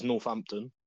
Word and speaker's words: Northampton: But you know, I think a lot of people say it as Northampton. – Yes, I'm very sure that that's how it's said.